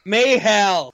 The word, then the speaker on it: Mahal: The Universal Champion, Brock Lesnar, and WWE Champion, Jinder Mahal. – Mayhal. – Was it Hal? Okay. – Yes. – Well, I almost said it right. It's Mayhal. Terrible.